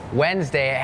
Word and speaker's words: Wednesday: เห็นละครหายใจเฮือกว่าเริ่มเลยก่อนเริ่มรายการพูดถึงคำว่าอะไรน้องๆจำได้ไหมคะ Silent letters ใช่ไหมคะนั่นก็คือตัวอักษรที่ไม่ออกเสียงแต่อย่างคำนี้ค่ะเป็นคำหนึ่งที่มี silent letters Wednesday has that D W E D